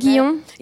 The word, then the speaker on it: Guyon: Madame Guyon, I don't say it right. Madame Guillon, Yeah, yeah, I love her.